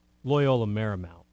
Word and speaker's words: Loyola Marymount: Loyola Marymount.